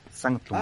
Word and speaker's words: Sanctum: Y obviamente empecé Es Sanct eh, Sanctum, no, no sé cómo se pronuncia. S-A-N-C-T-U-M. Sanctum.